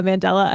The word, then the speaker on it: Mandela: I felt like almost like a mandala effect or mandala effect. I guess it's pronounced like the person's name. – Oh yeah. It is because of Nelson Mandela.